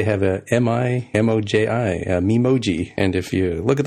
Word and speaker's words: Memoji: I think at this year's WWDC, I heard a lot of the presenters in the keynote pronounce it as Memoji.